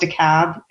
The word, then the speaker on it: DeKalb: This is how the DeKalb, and it's pronounced DeKalb in Georgia.